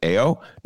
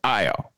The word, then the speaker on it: Ayo: First question people said, Ben, the name is spelled A-Y-O. Shouldn't it be pronounced A-O? No, it's I-O, okay? – A-O no is one spelled.